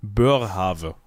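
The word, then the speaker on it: Boerhaave: Börhave. Burr. Burr, o e wird das so ausgesprochen? Oh, gut. OE wir wird haben, immer U gesprochen.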